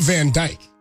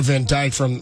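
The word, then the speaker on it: Van Dijk: No, it is spelled D-I-J-K, though. That's okay. – Dyke, I guess, in Dutch, maybe? – Fair enough.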